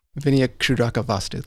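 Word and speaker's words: Vinayakshudrakavastu: And I'm going to see if I say it right. The Vinayakshudraka Vastu. – Vinayakshudraka Vastu, that's right.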